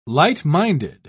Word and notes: light-minded: British English

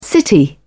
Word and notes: the city: British English

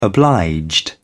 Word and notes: obliged: British English

